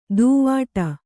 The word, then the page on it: ದೂವ್ವಾಡ: Kannada